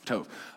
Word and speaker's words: tov: Okay, that word is tov, or tov. Yeah, I said it right. What am I questioning myself? Tov.